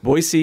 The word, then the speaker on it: Boise: How would you characterize the musical background of your time in, in am I saying it right? Boise? Boise, yes, with the S, not a Z.